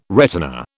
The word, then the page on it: retina: British English